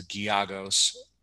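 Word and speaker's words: Gallegos: We'll see what the line comes out at, but you know, the pick is Gyagos. But I have in my notes that it's Gyagos, like with a Y.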